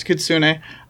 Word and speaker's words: Kitsune: I think Kitsune is how you pronounce that. – Yep, it's Kitsune.